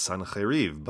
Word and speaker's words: Sennacherib: In Biblical Hebrew, it's pronounced Sennacherib, but in Akkadian, it's Sin-Ahiriba.